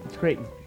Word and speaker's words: Crichton: B, Michael Crichton. I think is how you say that. Creighton? – It's Creighton.